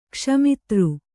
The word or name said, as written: ಕ್ಷಮಿತೃ